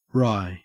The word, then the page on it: wry: Australian English